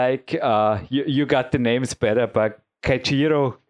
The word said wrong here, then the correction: Keijiro: Korenaga. Ah, Keijiro.